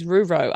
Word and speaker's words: RuRo: I also love you shortened it to RuRo. Is that right? Am I saying that correctly? Rural romance is RuRo.